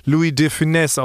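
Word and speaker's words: Louis de Funès: Louis Define wird Louis Definesse ausgesprochen, scheinbar. Das S spricht man mit.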